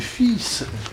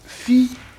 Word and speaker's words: fils: Évidemment, c'est fils que l'on dit et que l'on... – Ou fille. – Ah, fille, c'est l'ancienne prononciation.